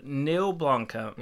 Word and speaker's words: Neil Blomkamp: So Neil Blomkamp, is that how you say it? – Yeah.